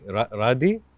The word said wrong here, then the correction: Radhe: Radhe. – Radhe. Okay, Radhe. Yes.